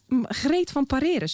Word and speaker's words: Greet van Pareren: Greet van Pareren. Spreek je nou goed uit, Greet? – Ja, je spreekt het uitstekend uit.